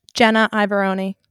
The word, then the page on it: Jenna Iavaroni: female